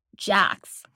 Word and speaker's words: Jacques: No, if you listen to the way they pronounce his name, they pronounce it Jax, like with an S, as opposed to just Jacques, which is how it's spelled. Like it's a French name.